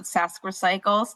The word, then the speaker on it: SKRecycles: We pronounce it Saskrecycles, but it's spelled skrecycles.ca, where you can find out all about our program and kind of what materials are accepted.